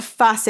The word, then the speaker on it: Fase: And then it's called Fase. It's F-A-S-E, phase, but it's spelled with an F, one.